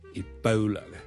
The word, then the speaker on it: Ebola: Ladies and gentlemen, boys and girls, hi, 真故事要跟最近咧，如果大家有睇国际新闻咧，都应该会留意到 Ebola 呢个伊波拉病毒咧喺某啲地方肆虐嘅。 Ebola。伊波拉病毒呢，我哋虽然广东话我哋译住伊波拉波啦，我哋系 O 呢个发音，但如果系英文呢，我哋通常呢系读 Ebola 嘅 Ebola，煲煲，好似弓箭嘅弓嗰个煲，OK，咁所以呢唔系 Ebola，而 Ebola， Ebola。